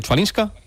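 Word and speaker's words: Chwalińska: Y mañana la final femenina a partir de las 3 de la tarde entre Andreeva y a ver si lo pronuncio bien, Chvalinska. – Sí, está bien dicho, la verdad.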